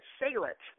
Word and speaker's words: Salit: It's I pronounce it Salit, like sail the boat.